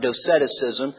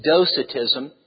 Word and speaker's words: Docetism: I have one too many syllables in your notes. It's not Doceticism. It's Docetism. And let's see.